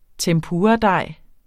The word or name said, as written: tempuradej